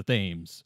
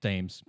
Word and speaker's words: Thames: So the group, our heroes, run back to the Yggdrasil and prepare to rendezvous with the Thames. I'm not pronouncing that right, but Thames. – Yeah, Thames.